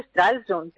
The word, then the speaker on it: Stralsund: Stralsund. ¿Cómo se dice? Buenos días. – Buenos días, ¿qué tal? Se dice Stralsund